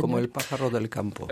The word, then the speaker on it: Como el pájaro del campo: Eh bien écoutez, on va écouter la chanson qui s'appelle Como Pajaro del Campo. Je prononcerai mal espagnol. – Como el pájaro del campo. – Voilà, c'est mieux.